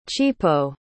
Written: chipo

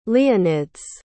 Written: leonides

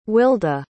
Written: willda

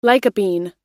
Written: lycopene

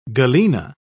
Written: galena

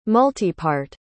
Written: multipart

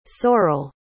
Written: Sorell

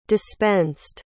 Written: dispensed

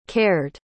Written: cared

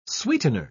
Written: sweetener

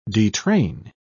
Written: detrain

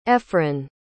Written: efren

